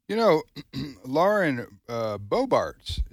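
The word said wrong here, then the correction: Boebert: is that the way you pronounce her last name? – Bobert? – Yep. – Bobert, she's the gunslinger.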